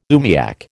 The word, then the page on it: oomiak: American English